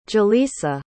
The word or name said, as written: julisa